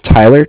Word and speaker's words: Tyler: My name is Tyler, T-Y-L-E-R, and I'm calling with Cardiff. It's spelled C-A-R-D-I-F-F, as in Frank.